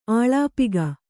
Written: ಅಲ್ಪಾಕ

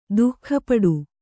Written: ದುಃಖಪಡು

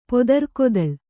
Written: ಪೊದರ್ಕೊದಳ್

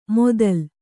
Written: ಮೊದಲ್

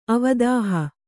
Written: ಅವದಾಹ

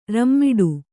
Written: ರಾಮಠ

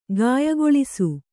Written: ಗಾಯಗೊಳಿಸು

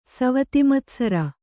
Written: ಸವತಿಮತ್ಸರ

